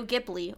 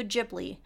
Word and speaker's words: Ghibli: And as we were doing Pixar, I made a comment to Hannah that I wanted to do Studio Ghibli or Studio Ghibli. It can be pronounced either way, however you prefer.